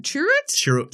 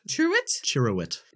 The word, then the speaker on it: Chirrut: Chirrut? – Chirrut. – Chirrut? – Chirrut.